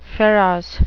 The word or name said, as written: pharos